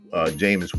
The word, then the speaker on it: Jameis: There's something about the Saints that I knew even this past summer was going to be the eye-opener as far as what Jameson does. Jameis Winston. I said Jameson. Excuse me. Jameis Winston. Let me get the name right.